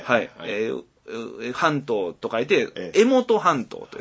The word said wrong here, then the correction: 江友半島: と読みますと。 – 江は読みますね。江本がちょっと読めやすい。あ、ごめんなさい。江友半島です。